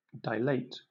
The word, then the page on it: dilate: British English